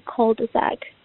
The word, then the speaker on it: cul-de-sac: Um, I just wanted to point out that this is a French word, and in French you don't pronounce the S at the end of words. So the plural form should still be cul-de-sac.